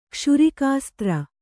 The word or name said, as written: ಕ್ಷುರಿಕಾಸ್ತ್ರ